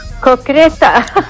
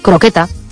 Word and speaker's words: croqueta: Se acepta cocreta porque como hay gente que lo pronuncia muy mal, pues entonces han decidido que vale igual. Pero bueno, la palabra correcta es croqueta.